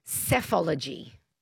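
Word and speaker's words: psephology: It's and it's pronounced cephology.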